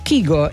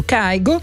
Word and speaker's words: Kygo: Abbiamo scoperto che si può pronunciare in entrambi i modi, perché in norvegese Kygo, in inglese o insomma per tutto il mondo Kygo, insieme a Max McNown, Take Me Back.